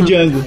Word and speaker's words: Django: o D é mudo. Aí o cara fala, eu sei, kid, eu sei. É esse cara, é o Django.